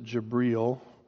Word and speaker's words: Jibril: Another man in history that had an experience was Muhammad, who had an experience from the angel, they pronounce it Jibril, but what they're saying is Gabriel.